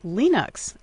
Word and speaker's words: Linux: He says we should say Linux, not Linux, and sent along a sound clip of the creator of the popular operating system who's from Finland and whose name I'm not even going to try to pronounce. Anyway, here he is offering his suggested pronunciation. – Hello, this is Linus Torvalds, and I pronounce Linux as Linux.